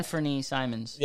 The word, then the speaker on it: Anfernee Simons: Honestly, this, what, I can't pronounce it, Anfernee Simmons, like Anfernee Simons,